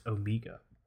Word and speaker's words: Omega: Omega. It's pronounced Omega.